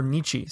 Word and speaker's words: Nietzsche: We can think of Nietzsche, or Nietzsche, some pronounce it, who, you know, was basically a nihilist.